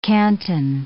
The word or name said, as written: canton